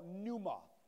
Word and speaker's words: pneuma: If you look at the word spirit right there in the Greek, you'll see a word that's called pneuma.